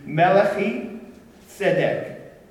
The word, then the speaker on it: Melchizedek: For this Melchizedek, and the Hebrew, his name, how we would pronounce it, would be Melechi Tzedek.